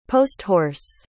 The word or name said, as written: post-horse